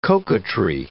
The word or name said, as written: coquetry